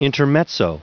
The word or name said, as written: intermezzo